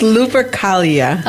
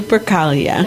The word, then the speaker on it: Lupercalia: It's Lupercalia. Okay. – Oh dear, that was fancy pronunciation. – Mm. Lupercalia.